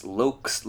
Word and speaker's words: Lokz: It's Lokes, like the shades, but just with an L-O-K-Z.